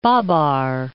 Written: Babar Islands

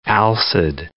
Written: alcid

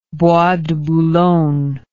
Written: Bois de Boulogne

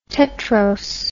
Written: tetrose

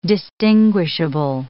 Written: distinguishable

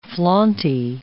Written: flaunty

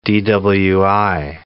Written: DWI

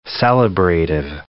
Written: celebrative

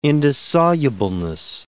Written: indissolubleness

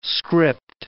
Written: script

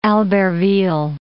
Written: Albertville

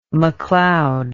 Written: McLeod

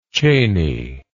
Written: chaney

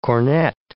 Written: cornet

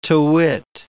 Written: to wit

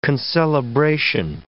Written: concelebration

